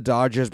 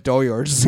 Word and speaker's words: Dodgers: it's crazy how Mexicans love the Dodgers, but they can never pronounce it. It's always Doyers. – Or Yodgers, man.